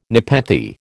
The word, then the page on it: nepenthe: American English